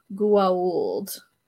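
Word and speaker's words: Goa'uld: So it's spelled G-O-A apostrophe U-L-D. So it's supposed to be pronounced Gould. Do sometimes say it that way, but the Tok'ra were like, we are not Gould. And I'm like, okay. – Gould. They just decided the O doesn't count.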